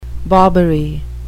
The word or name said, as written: bobbery